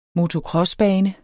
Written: motocrossbane

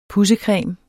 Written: pudsecreme

